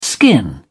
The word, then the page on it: skin: British English